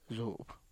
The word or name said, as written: zup